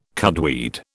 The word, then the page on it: cudweed: British English